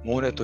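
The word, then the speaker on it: 舞根: モーネってどういう字を書くかというと、踊りを舞うの舞うという字と根っこの根を書いてモーネと読みます。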